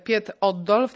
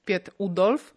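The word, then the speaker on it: Piet Oudolf: No tutaj główną osobą, taką najbardziej znaną, może Państwo słyszeliście takie nazwisko, jak Piet Oddolf, tak naprawdę czyta się Piet Udolf.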